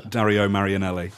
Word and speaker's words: Dario Marianelli: Dario Marianelli.